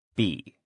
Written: be

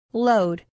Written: load

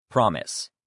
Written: promise

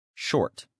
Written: short

